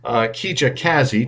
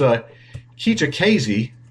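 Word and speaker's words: Kijakazi: The report said at a congressional hearing earlier this year, SSA Commissioner Keija Kazi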